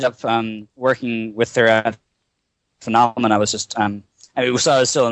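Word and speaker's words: Funomena: and then you went on to work at Funamina, correct? – Yeah, the pronunciation is phenomena, like phenomena, but, Okay.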